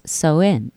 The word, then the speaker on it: Samhain: For my purpose, I'm going with the ancient Celtic festival of Samhain, pronounced So-in.